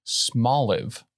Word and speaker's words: Smoliv: It's pronounced Smoliv. – Oh my God. – Small olive.